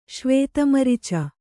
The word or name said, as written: ಶ್ವೇತಮರಿಚ